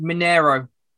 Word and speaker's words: Mineiro: It's pronounced Mineiro. Ronaldinho played. – I wasn't far off.